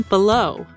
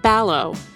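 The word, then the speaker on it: Balogh: He refers to one of the historians interviewed as Brian Ballow, but the University of Virginia professor's name is actually pronounced Ballow.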